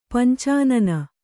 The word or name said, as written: ಪಂಚಾನನ